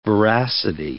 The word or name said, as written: veracity